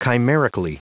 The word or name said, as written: chimerically